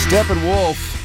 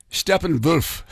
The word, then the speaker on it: Steppenwolf: That's Steppenwolf, Born to be Wild, Q104.3. I'm thinking back to my dad. He would always call them Steppenwolf, like with the German pronunciation of a W being a V, only because there was wasn't the book Steppenwolf?